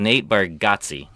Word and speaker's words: Nate Bargatze: called Nate Bargatze.